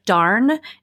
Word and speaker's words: darne: So instead of just doing a traditional salmon fillet, there's a cut called the darn. It's spelled D-A-R-N-E.